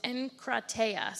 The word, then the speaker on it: enkrateia: But it's enkrateia, so E-N-K-R-A-T-E-I-A, enkrateia.